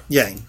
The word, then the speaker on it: Yang: Jin-Lun Wang. – Jin-Lun Yang. Is it Yang or? – Yang.